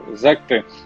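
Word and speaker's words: Sekt: Секты, как по-немецки это произносят, это игристые вина Германии и Австрии. В то же время э, игристые вина, которые производят в Богемии, в Чехии произносят на славянский манер. Mm-hmm. – Не по-немецки зект, а сект.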